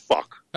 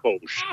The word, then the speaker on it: Foch: It's fuck. It's spelled F-O-C-H, but it actually is pronounced Fosh, a little Fosh or something.